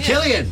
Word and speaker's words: Cillian: Cillian. Cillian Murphy.